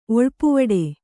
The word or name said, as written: ಒಳಪಾವುಡೆ